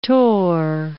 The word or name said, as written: tore